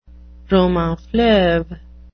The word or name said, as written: roman-fleuve